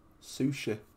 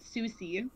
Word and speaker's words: sushi: Pronounce that word. – Sushi. – Yes, I know someone who pronounces it Susie.